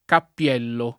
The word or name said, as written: Cappiello